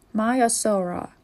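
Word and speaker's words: Maiasaura: Myosaura.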